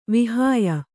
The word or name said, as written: ವಾಹ್ಯ